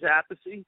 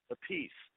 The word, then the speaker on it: Appice: So Vinny is Appice, and Carmine's a piece.